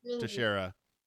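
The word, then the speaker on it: Texeira: Is that how you pronounce it? – Teixeira.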